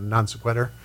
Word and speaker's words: non sequitur: We have a few minutes left, and I thought sudden, what do they call it, non sequitur.